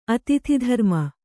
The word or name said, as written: ಅತಿಥಿಧರ್ಮ